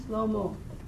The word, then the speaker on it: Shlomo: The mo in the name Shlomo ends with that syllable. Shlomo. Slow motion.